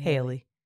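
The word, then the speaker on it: Halle: How do you say that girl name? – Haley. – Haley? We don't know. – Haley. – Somebody. – It's definitely Haley.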